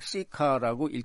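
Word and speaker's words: CICA: 영어 약자로 CICA라고 하는데 그것을 그냥 시카라고 읽습니다.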